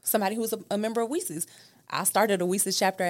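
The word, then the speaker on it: WiCyS: It's WESIS, Women in Cybersecurity, and it's pronounced WESIS like We Sisters.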